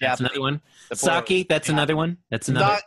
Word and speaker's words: sake: Two, the pronunciation is sake. Sake.